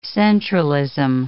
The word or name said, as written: centralism